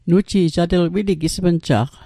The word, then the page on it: wili: Mi'gmaq